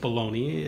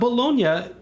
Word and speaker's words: Bologna: You know, not pronounced Bologna as many Americans say, but Bologna is often explained as you know,